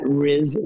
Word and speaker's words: Riz: And they can follow me on Twitter, or on X, I should say, at Riz Stanford, which is spelled just like R-I-Z at Stanford, the university as well.